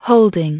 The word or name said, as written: holding